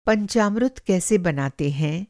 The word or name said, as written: पंचामृत